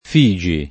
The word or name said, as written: Figi